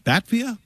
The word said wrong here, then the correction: Batavia: Batavia? – Batavia. Batavia.